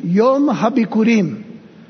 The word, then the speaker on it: Yom HaBikurim: Yom HaBikurim is how it is pronounced.